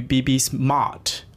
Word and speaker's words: Beebiesmart: 明白。那接下来对于 BB Smart OK，是 M A R T。我现在要重新更正我的发音。